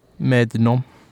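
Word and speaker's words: Mer de Noms: Medinom.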